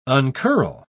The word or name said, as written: uncurl